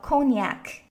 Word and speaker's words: cognac: 在法语当中，它叫 cognac。